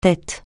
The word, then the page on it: tête: francais